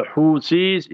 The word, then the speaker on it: Houthis: So the Houthis in Yemen. So Houthi will be H-O-U-T-H-I-S.